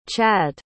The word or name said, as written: Chadd